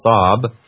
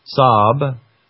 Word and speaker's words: Saab: Just type S-A-A-B. That's how you pronounce it, or how you spell Saab. Saab.